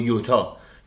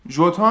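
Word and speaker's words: Jota: ژوتا و دیگو یوتا جوتا هم حالا درسته یوتا وجود نداره چون پرتغالیه ولی آیاکس هم تو خود هلند میگن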